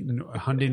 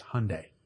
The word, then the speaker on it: Hyundai: So officially, Hyundai North America has decided their pronunciation is Hyundai. – Huh, they've given in. It's in Korean, it's Hyundai.